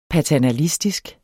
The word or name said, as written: paternalistisk